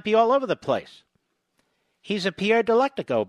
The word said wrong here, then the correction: Delecto: bot, or Delecto, may I say.